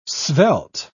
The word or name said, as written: svelte